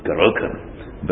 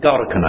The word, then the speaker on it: Gorokan: I want as a Welshman to pronounce it Gorokan, but Gorokan I believe it is.